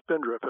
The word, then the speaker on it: spindrift: And it's easy to remember the term spindrift. It rhymes with spendthrift.